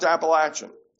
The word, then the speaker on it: Appalachian: And yes, it's pronounced Appalachian. There's no long A in the word. People think there is, it's not.